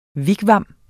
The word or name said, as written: WIGWAM